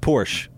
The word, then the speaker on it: Porsche: It's pronounced Porsche. – My bad. – It's Porsche. It's actually a German name. It's Porsche. Porsche. That's how you say it.